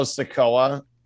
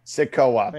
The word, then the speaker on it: Sikoa: I just got to point out, Chad, it's Solo Sikoa. A sequoia is a kind of tree. – I keep fucking saying it wrong. I'm sorry. – But I keep That's cracking up. You're fine. Sikoa.